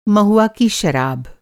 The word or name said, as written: महुआ